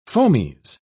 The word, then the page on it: fomes: British English